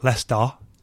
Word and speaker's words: Leicester: Leicester lad, Leicester, is how you say it properly.